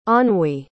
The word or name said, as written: anhui